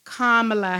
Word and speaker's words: Kamala: And as one person said, I think Don Lemon said it, think of your comma in a sentence and just put la behind it. Comma, la. And he was like, Kamala, Kamala.